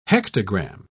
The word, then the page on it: hectogram: British English